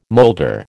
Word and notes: moulder: American English

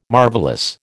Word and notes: marvellous: American English

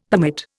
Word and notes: permit: Indian

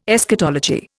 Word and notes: Eschatology: Indian

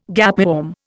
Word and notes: gapeworm: Indian

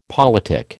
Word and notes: politic: American English

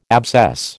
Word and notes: abscess: American English

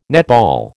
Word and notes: netball: American English

